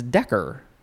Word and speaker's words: decare: It is spelled D-E-C-A-R-E. I would want to pronounce it decare, but it is it's decker, basically. Noun from 1810, a metrical unit of area, equal to 10 ares, A-R-E-S, or 0.2471 acre.